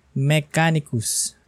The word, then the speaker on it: Mechanicus: Next one on the list is Warhammer 40,000 Mechanicus. Tama ba yung pag-pronunciation ko? Mechanicus.